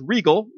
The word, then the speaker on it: Riegel: One is Riegel, which rhymes with Spiegel, which is why I said Riegel and Spiegel. But Riegel is a curb. A curb, C-U-R-B.